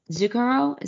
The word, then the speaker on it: Zucchero: Bono's going to be on Zucchero. Is that how you pronounce his name? – Yes.